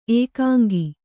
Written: ಏಕಾಂಕ

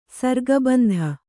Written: ಸರ್ಗಬಂಧ

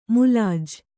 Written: ಮುಲಾಜು

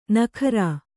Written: ನಕ್ಕರಿ

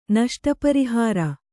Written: ನಷ್ಟಪರಿಹಾರ

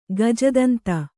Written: ಗಜದಂತ

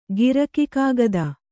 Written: ಗಿರಕಿಕಾಗದ

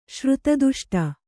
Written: ಶ್ರುತದುಷ್ಟ

